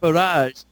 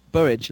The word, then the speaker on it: Burrage: You can say it that way if you want. I say Luke Burridge. – You say Luke what? – Burridge. Burridge. Luke Burridge.